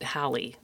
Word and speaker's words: Halle: But, anyways, the first tip is one I actually got from Haley Berry. Halle Berry? Is it Hallie or Haley? – I believe she pronounces it Hallie.